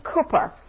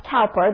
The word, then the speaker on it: Cowper: It's been written by a man called William Cowper. It's not pronounced Cowper, though that's how it's spelled, but it's William Cowper.